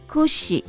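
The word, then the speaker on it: 櫛: 这个梳子那个的发音哈，kushi，好，那个 ku 就是苦哈，跟苦是同样的音，是跟死是同样的音，所以会让人联想到苦跟死哈。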